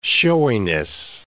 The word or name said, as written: showiness